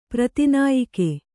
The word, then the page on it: ಪ್ರತಿನಾಯಿಕೆ: prati nāyike